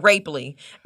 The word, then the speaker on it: Rapley: Yeah, I know they are, because it's not Rapley.